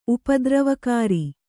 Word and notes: ಉಪದ್ರವಕಾರಿ: upadravakāri

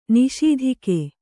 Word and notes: ನಿಸ್ತೂಕ: kannada

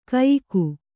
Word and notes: ಕಯಿಕು: kayiku

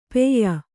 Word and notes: ಪೇಯ: pēya

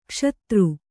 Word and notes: ಕುಶದ: kuśada

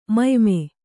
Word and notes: ಮುಯ್ಮೆ: muyme

